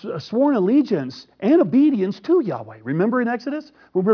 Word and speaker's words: Yahweh: And so we're not really sure, but most scholars believe that the way it should be pronounced is Yahweh.